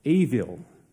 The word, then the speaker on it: ewil: In fact, the Hebrew word for fool is spelled E-W-I-L. And in Hebrew, W has a V sound. Avil.